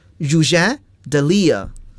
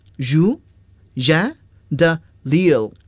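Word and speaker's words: Rouget de Lisle: 好了，这个词呢是典型的法语词，我们要按法语来发音了。 Roger de Lille, Roger de Lille, Roger de Lille, 这是著名的马赛曲的作者。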